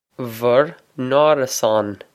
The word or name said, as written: bhur n-árasán